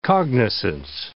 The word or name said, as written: cognizance